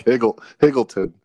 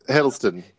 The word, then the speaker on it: Hiddleston: But I will also say, Tom Hiddleston, how do you say his last name? – I like Higgleton. It's Hiddleston.